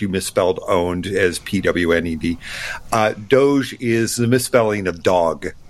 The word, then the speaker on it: DOGE: So this story broke, and this is the DOGE department, D-O-G-E. I'm assuming that's how you pronounce it, right? – Do we say DOGE or DOGE? – It's DOGE.